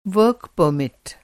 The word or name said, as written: परमिट